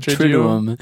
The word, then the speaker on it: Triduum: But then this word like Triduum, did I just say that right? – I think so. Triduum.